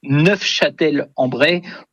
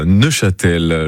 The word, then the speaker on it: Neufchâtel-en-Bray: Et en effet, pour les puristes, dont je ne suis pas, puisque je n'ai pas prononcé le F, mais pour les puristes, en effet, il faut prononcer Neufchâtel-en-Bray pour, en effet, peut-être se différencier de la ville de nos amis suisses.